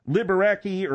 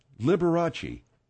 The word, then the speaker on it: Liberace: I don't care if it says Liberace. Liberace. – Well, how do you know? – Because that's the way it's pronounced.